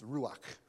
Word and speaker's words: ruach: The meaning of that word is pronounced ruach, and it means like wind or breath, from which you can't see where it comes from.